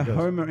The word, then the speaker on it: Houma: So the Homer Indians, meaning Homer is spelled Houma. If you're wondering, we're thinking it was Homer Simpson.